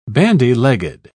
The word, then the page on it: bandy-legged: British English